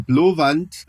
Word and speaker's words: Blåvand: In der Tirpitz-Stellung in Blåvand, so würde man es norwegisch aussprechen, ich weiß nicht, wie man es dänisch ausspricht, das klingt ja mal, als ob die eine heiße Kartoffel im Mund haben und norwegisch reden die Dänen.